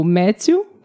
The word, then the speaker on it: Matthew: Nós conhecemos já o Matthew, falei certo uhum, o nome dele? – Isso.